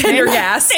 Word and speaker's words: Pendergast: Well, Tom Pendergrass. – Yeah. – I totally mispronounced that last Pendergast.